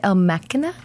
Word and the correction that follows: Ex Machina: Ex Machina.